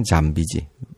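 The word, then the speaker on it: zombie: 이제 그쪽에서 발음 잠비지, 음. 잠비라든가 뭐 좀비 이렇게 하시면 되는데 여기서는 무엇을 뜻하냐면 여기에서 잠비는 그 IRA 아시죠? – 네.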